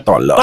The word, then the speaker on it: Tolosa: En francés es la ciudad de Toulouse. – Toulouse, ajá. – Ajá. Pero en español se pronuncia Tolosa.